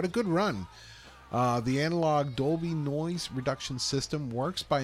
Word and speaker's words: Dolby: This day, 2013, Ray Dolby, the U.S. engineer who founded... – Also known as Dubley.